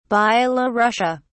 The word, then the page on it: byelorussia: British English